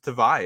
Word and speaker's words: Tavai: And you meet him in person, you find out the pronunciation on the Patriots site that he is saying isn't – it's just Tavai is his last name.